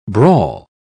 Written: brawl

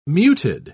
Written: muted

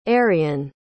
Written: arrian

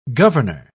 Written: governor

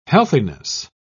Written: healthiness

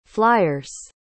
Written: flyers

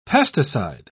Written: pesticide

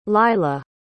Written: lila

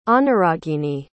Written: anuragini

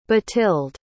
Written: bathild

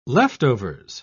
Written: leftovers

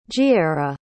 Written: jiera

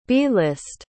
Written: b-list

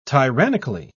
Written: tyrannically

